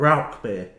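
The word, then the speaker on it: Rauchbier: And don't get me started on Rausch beer. I didn't know I was saying Rausch beer wrong. – Isn't that Rauch beer? – Rauch.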